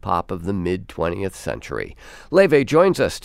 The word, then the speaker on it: Laufey: Her name looks like Laufey, but it's pronounced Laufey.